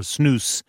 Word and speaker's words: snus: Now, the word comes from the Swedish name for snuff, and it's pronounced either snus or snus in America.